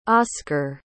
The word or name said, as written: oskar